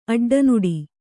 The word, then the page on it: ಅಡ್ಡನಾಡಿ: aḍḍanāḍi